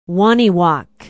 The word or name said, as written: wonewoc